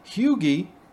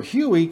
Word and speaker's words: Huj: In Russian, the chant pronounced phonetically, Huge, or Huey, coincidentally means penis, or more accurately, prick. In Polish, the word is spelt Huj, H-U-J, but pronounced similarly.